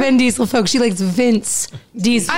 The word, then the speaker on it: Vin Diesel: Why am I saying that wrong? – Are you sure he's your celebrity crush? – Vince Diesel. – Who's Vince Diesel? – Vin Diesel. Oh, Jesus Christ.